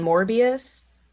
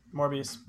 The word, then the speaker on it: Morbius: He has it in Morbius. – Yeah. – Yeah, that's how you say it.